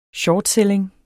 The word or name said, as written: shortselling